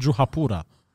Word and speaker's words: Juhapura: Yeah, in shrubs near the hotel in Juhapura. – Oh, that you said that properly. I felt like I was listening to a real Indian.